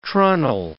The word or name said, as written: treenail